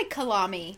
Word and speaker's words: Kalami: K-A-L-A-M-I. – I say Kalami. Kalami. You know, it sounds like Navani and that sort of jive.